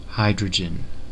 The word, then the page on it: hydrogen: American English